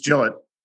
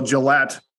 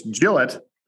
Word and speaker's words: Gillette: Not so much emphasis on the L. It's just Gillette. You know, you guys are doing a little Gillette. It's Gillette and then just Searing.